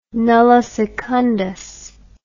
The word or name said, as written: nulli secundus